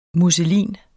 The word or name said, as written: musselin